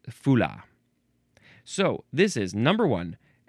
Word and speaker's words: Fula: Fula. Fula is next. I think that's how it's pronounced. F-U-L-A with a capital F.